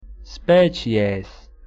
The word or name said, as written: species